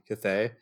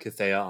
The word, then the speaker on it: Cathay: So even though I have always read Cathaya on the page, and I said Cathaya for a long time, I have settled on the idea that it is in fact pronounced Cathay.